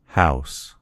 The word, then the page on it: house: American English